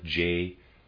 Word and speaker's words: J'accuse: He says, J'accuse, and I'm going to spell that J'ACCUSE, excuse me, I'm going to pronounce that J'accuse.